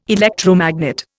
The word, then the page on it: electromagnet: Indian